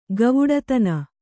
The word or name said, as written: ಗವುಡತನ